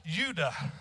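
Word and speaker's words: yadah: The second word is pronounced Yuda. It means to worship with extended hands.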